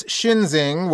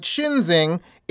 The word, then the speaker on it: Schinzing: We should note that Shinzing is not exactly a common name. It's spelled S-C-H-I-N-Z-I-N-G.